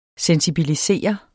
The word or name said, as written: sensibilisere